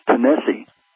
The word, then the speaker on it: Tennessee: I agree with that wholeheartedly, and certainly it's no secret that the state of Tennessee is properly pronounced Tennessee. It is a Cherokee name,